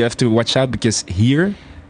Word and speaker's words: heer: When I say H-E-E-R, it's pronounced here.